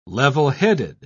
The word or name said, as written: levelheaded